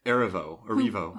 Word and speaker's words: Erivo: But something, so those 27 takes, Cynthia, how do you say her last name? – Cynthia Erivo.